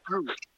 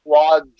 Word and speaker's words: Gwosdz: No, so I didn't recognize that because of the pronunciation, because Goosh, the way that it's spelled, it's like Goosh. G-W-O-S-D-Z. It looks like it should be Gwaz.